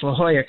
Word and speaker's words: La Jolla: And you even pronounced La Jolla correctly, which is hard.